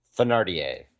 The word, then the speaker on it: Thénardier: Thénardier.